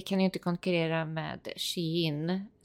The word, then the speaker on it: Shein: Shein. – Ja. Uh, because she's in style. Shein.